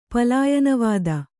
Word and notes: ಪಲಾಯನವಾದ: palāyana vāda

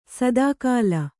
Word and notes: ಸದಾಕಾಲ: kannada

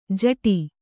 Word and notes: ಜಟಿ: Kannada

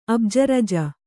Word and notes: ಅಬ್ಜರಜ: abjaraja

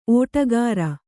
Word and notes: ಓಟಗಾರ: ōṭagāra

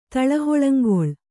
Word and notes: ತಳಹಳಂಗೊಳ್: Kannada

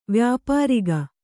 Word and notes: ವ್ಯಾಪಾರಿಗ: kannada

